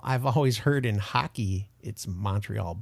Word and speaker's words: Montreal: It's V-I-V-A-F-R-E-I. And he lives in Montreal, Canada, in Quebec. – Montreal. It's Montreal.